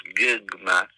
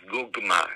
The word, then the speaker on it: gugma: For example, the word for love in Bisayan is gugma. In a language that preserves the schwa sound, it'll be pronounced gugma, but otherwise gugma.